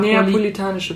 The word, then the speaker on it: neapolitanische: zur napolitanischen Pizza. Mhm. Könnt ihr das aussprechen? – Ich kann es nicht aussprechen. Neapolitanische Pizza. Oh, ich wollte Napoleon sagen.